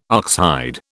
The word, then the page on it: oxhide: British English